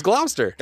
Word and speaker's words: Gloucester: If you guys remember the Gloucester part that we were laughing about, it's apparently pronounced Gloucester. – No. – No, I don't believe it. – It's not Gloucester. Gloucester. – It's Gloucester.